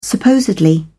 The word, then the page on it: supposedly: British English